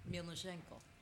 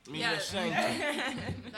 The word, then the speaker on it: Mielnichenko: But you have to say it like Mielnichenko. – Mielnichenko. – Like you're slightly threatening.